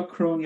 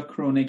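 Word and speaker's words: Crone: پاتریشیا کرون یا کرونه، که البته خود ایشون اسمش رو کرون تلفظ میکرد ولی کرونه هم تلفظ میشه و هیچ مشکلی نیست، جز تجدید نظر طلبهای افراطی حساب میشه.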